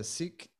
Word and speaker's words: Sikh: You actually said it right the first time. I mean, I usually say Sikh too, but the proper pronunciation is Sikh.